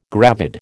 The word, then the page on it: gravid: American English